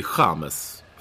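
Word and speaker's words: James: Men däremot så säger vi Chamez, för att så uttalar man det i Colombia, eller hur? – Ja, men...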